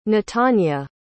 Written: Netanya

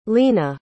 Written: leana

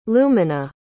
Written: Lumina

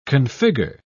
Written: configure

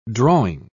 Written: drawing

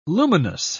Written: luminous

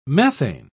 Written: methane

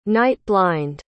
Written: night-blind

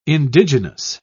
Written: indigenous

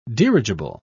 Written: dirigible

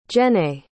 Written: Jenay

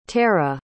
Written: tera

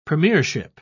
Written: premiership

